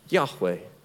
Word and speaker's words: Yahweh: That when God reveals his name, YHWH, that it would have been pronounced in that day, Yahweh.